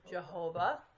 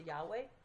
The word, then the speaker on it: Yahweh: We used to pronounce it Jehovah. They know that that's really off. So they think it's closer to Yahweh.